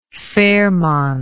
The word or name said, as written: fairmont